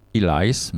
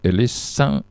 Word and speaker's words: Ellis: Robert Ellis, Ellis c'est un prénom et un nom de famille courant au Pays de Galles. Il se prononce toujours Ellis, pas Elias, mais Ellis.